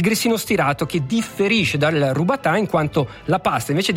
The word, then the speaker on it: rubatà: La forma più antica e tradizionale del grissino è il robatà, che bisogna pronunciare come rubatà, che significa rotolato, lungo dai 40 agli 80 centimetri.